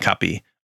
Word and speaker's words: Kapi: But there's Kumbakonam degree coffee, Mylapore filter coffee, and Madras Kapi and Kapi, which again is sort of supposed to sound phonetically like the word coffee.